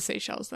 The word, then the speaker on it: Seychelles: It's spelled S-E-Y-C-H-E-L-L-E-S. And I like to start with this one, or S-E-Y-C-H-E-L. – Eswatini? – No. S-E-Y-C-H-E-L-L-E-S. – S-E-Y.